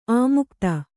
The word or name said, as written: ಅಮುಕ್ತ